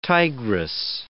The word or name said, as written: tigress